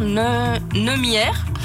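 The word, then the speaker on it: Neumeier: Edward Neumière. – C'est à peu près ça.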